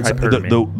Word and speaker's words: hypergamy: Do you say hypergamy or hypergamy? – The word is hypergamy. – Yeah. – If you pronounce it from the Greek, it's hypergamy.